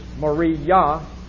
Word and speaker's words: Moriah: I looked up the pronunciation of it, and I believe the proper pronunciation is Moriah.